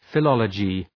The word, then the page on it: philology: fı’lɒlədʒı